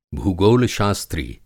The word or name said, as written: भूगोल-शास्त्री